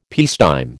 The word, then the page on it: peacetime: American English